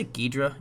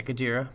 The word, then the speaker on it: Gojira: I always say Ghidra, even though I know that's not how you pronounce it. – Gojira? – Yeah, Gojira.